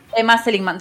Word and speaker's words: Emma Seligman: Y me encanta Emma Seligman, está bien, ¿no? Lo digo bien el nombre de la directora, porque yo... – Sí, Emma Seligman.